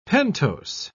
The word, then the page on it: pentose: British English